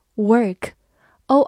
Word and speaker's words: work: 最后注意对比一个近音词，w o r k 读作 work，o r 字母组合发 er 这个音。